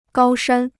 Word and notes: 高山: gāo shān

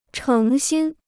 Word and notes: 成心: chéng xīn

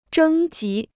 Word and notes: 征集: zhēng jí

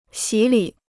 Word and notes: 洗礼: xǐ lǐ

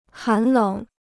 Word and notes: 寒冷: hán lěng

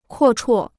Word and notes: 阔绰: kuò chuò

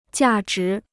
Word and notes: 价值: jià zhí

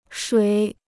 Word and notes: 水: shuǐ

